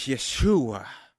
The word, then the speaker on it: Yeshua: The Hebrew, pronunci- the Hebrew pronunciation of Jesus' name is Yeshua.